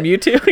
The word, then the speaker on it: Mewtwo: Mewtwo, or how you say it. – Mewtwo, yeah.